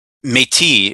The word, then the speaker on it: Métis: Métis is how it's said, and it's a full on recognized ethnic group in Canada, I think. Métis, Métis. And it's a French word, and I'm pretty sure it just means mixed.